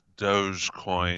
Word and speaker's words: Dogecoin: Do I think it's pronounced it's Dogecoin.